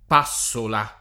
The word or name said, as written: passola